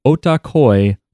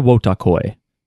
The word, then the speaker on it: Wotakoi: And today we are going to be covering Otakoi, Love is Hard for Otaku, or Wotakoi, if you want to pronounce the W.